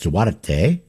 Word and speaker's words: Duarte: The family name is Portuguese in origin, and it would be in Portuguese pronounced Duarte, but in England and in his family, they pronounced it Dwart.